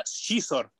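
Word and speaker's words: Xizor: Eh, eh, estuve buscando y se pronuncia Shizor, el príncipe Shizor.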